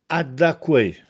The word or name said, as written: Addacquè